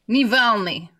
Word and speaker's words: Navalny: Is it Navalny? – Navalny.